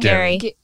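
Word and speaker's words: Gerry: What did I say? – Jerry. – I do the same thing. – Gary. – He's no longer the Golden Bachelor, so it won't matter. Gary. You could say it wrong.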